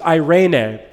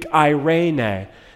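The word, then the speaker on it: eirene (εἰρήνη): This word peace is eirene, the Greek eirene. Just put it in your notes phonetically, eirene, peace.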